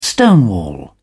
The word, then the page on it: stonewall: British English